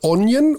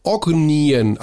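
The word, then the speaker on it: Ognjen: Ja, Kuzmic sagt Onjen und Dobrić sagt Ognien, also der spricht das G mit.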